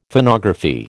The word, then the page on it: Phonography: American English